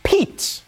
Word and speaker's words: Pete: It's pronounced Pete. Okay? It's like peaches, but you just stop.